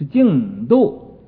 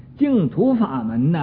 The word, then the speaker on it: 净土: 这个本来是个土字，但是啊，在这个佛经上应该读成一个度字啊，净度。有的没有读过书的人啊，没有什么学问的人啊，就说净土法门呢，净土法门啊，这不是净土法门，是净度法门，国度啊。这个字应该读成这个去声。